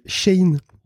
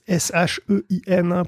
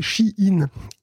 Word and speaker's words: Shein: Oui, et donc grand merci à PL, parce que effectivement, depuis toujours en fait, depuis que je connais la marque, je dis Shein pour le parce que c'est Shein pour cette marque de fast fashion. – J'aurais pas prononcé autrement. Moi. – Et en fait, on me dit donc c'est donc un auditeur qui me dit dans les commentaires que ça se prononce Shein.